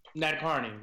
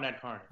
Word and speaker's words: Nadkarni: Nadkarni. Rohan Nadkarni.